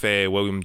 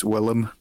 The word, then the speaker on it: Willem: While we're on that subject, I just had to say it's Willem Dafoe, not William. – Oh, is it Willem? – Willem.